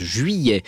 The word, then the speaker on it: juillet: Julien Bert avec ce titre de 1997, juillet 66, je vais y arriver, juillet 66, voilà, c'est comme ça qu'on prononce juillet.